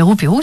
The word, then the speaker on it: Rouperou: c'est ce qu'on écoute à présent et c'est signé Roupirou et ça s'écrit exactement comme je le prononce. R-O-U-P-E-R-O-U. Et c'est signé, euh, ben, signé Roupérou sur leur album Roupérou EP, ou leur EP qui s'appelle Roupérou EP.